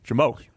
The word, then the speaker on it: JMOQ: Jamoke. – Jamoke. – That's how I pronounce it. J-M-O-Q.